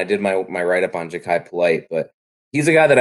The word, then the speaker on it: Polite: Also, as an aside, I think the guy getting mocked to the Lions more than anyone is that Polite guy. I don't know if that's how you say it, out of Florida. – Ja'Kai Polite, yeah.